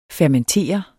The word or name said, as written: fermentere